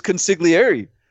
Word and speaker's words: consigliere: I mean, that, like, Marvin Lewis is already roaming the sidelines with Herm Edwards right now as, like, his consigliere. How to say it? – Consigliere. – Consigliere. – You could do the E. It's fine. It's no okay.